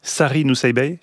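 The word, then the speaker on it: Sari Nusseibeh: Sari Nousseibeh, c'est comme ça qu'on prononce ? – Oui.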